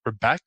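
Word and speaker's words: Rebbec: We saw Rebek is how you pronounce it, which is the white commander.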